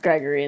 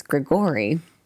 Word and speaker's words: Grigori: It's pronounced Gregory in the anime. That's why he was yelling. – Well, the anime has it wrong. It's Grigori.